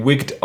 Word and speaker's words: WCKD: Und das wird wie Wigged ausgesprochen.